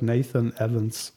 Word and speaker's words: Nathan Evans: Nathan Evans, hätte ich ihn ausgesprochen. – Evans, so. Habe ich auch gesagt, Nathan Evans.